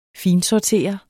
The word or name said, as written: finsortere